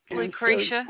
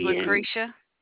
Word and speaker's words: Lucretia: Lucretia. – On 5/5/2021, approximately 2:34 p.m. – My name is Lucretia. – Lucretia, how do you spell that? – L-U-C-R-E-T-I-A.